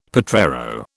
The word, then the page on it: potrero: British English